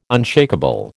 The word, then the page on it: unshakeable: American English